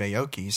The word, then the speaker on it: Aoki: Yeah, I read an article somewhere that Steve Aoki said, I hope I'm pronouncing his name right. – Yeah, you are.